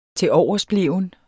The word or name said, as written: tiloversbleven